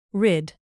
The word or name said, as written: rid